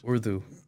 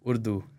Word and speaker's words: Urdu: Urdu. – Urdu? – Yeah. – How do you pronounce that? – Urdu. U-R-D-U.